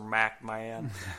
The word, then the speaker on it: McMahon: So Vince asks Briscoe if he will be an unbiased official, to which Briscoe responds, "Yes, sir, Mr. McMahon." I always enjoyed the way Briscoe pronounced Vince's last name. It was like Pac-Man with an M.